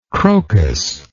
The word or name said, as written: crocused